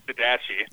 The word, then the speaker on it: Didache: And in fact, I use still John Pless's Didache book, which you pronounce Didache. – Didache. – And Didache.